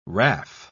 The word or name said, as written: raff